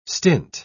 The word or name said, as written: stint